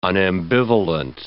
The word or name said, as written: unambivalent